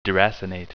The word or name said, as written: deracinate